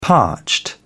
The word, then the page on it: parched: British English